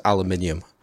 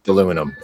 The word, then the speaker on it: aluminium: except for they draw the line at aluminium. They cannot and won't do that. – Aluminum. It's definitely aluminum, right? Like, I don't know. Like, is the United States the only place that says aluminum? – I think it is.